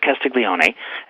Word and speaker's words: Castiglione: Her name was the Countess of Castiglione, and she was the cousin of Camillo Cavour, who was the Prime Minister of Piedmont.